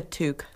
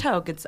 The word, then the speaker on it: toque: He refuses to wear the Chef Boyardee hat, which he called a toque. But the correct pronunciation is toque.